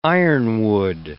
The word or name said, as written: ironwood